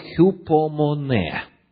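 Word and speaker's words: хюпомоне (ὑπομονή): Апостол Петр, оставив нам это послание на греческом языке, для описания терпения использует греческое слово «хюпомоне». Для тех, кто конспектирует, еще раз. «Хюпомоне». Ударение на последний слог.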